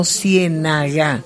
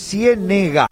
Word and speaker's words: Ciénega: Y una inquietud, si es Ciénega o Ciénaga. – No, es Ciénega, sí, con E. – Con E. – Con acento en la E, Ciénega.